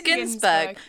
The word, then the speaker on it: Ginsberg: Now, is it Ginsberg or Ginsberg? – Oh. I think it's Ginsberg. – It's a hard G.